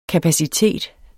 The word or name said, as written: kapacitet